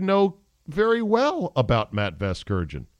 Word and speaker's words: Vasgersian: Vergasian, is that how you say it? – No, you're not saying it right.